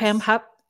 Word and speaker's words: Pam Hupp: Pam Hupp. Am I saying that correctly? Pam. – Yes. – Pam Hupp.